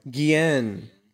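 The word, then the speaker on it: Guillén: Wait, what? Guillen. – Guillen. – Yeah. This is not this is not Jeffrey Epstein's Galeen. – This is a different person with the same last name. – No. Guillen is like Ghislain.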